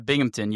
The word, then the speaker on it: Binghamton: You did end up going to, I can never say the name right, Binghamton? – Yeah, Binghamton, yeah.